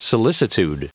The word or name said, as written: solicitude